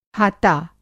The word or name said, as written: हाता